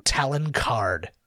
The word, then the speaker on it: Talon Card: Talon Card. – Talon, T-A-L? – Yeah, like the like an eagle talon.